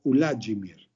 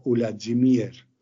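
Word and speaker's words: Ulajimir (Уладзімір): Y es en el idioma bielorruso donde encontramos que se pronuncia de manera distinta y se escribe de manera distinta, porque se utiliza la U como primera letra, y de ahí que entonces el nombre sea Ulajimir o Ulajimir.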